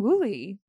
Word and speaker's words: Wooley: And my guest today is John Wooley.